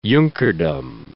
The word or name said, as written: Junkerdom